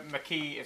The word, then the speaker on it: McKee: Gina McKay, uh, McKee is fantastic, and Angie.